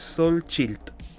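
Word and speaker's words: Xochitl: Eh, ah, aquí está la pronunciación correcta, es Sol Chilt.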